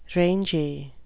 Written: rangy

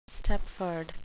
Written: stepford

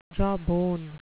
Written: jawbone

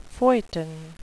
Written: feuilleton

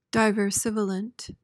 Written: diversivolent

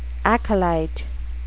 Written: acolyte

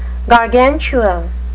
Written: gargantua

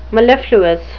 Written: mellifluous

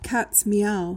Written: cats meow

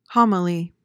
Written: homily

